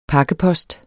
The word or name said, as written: Pakkeposten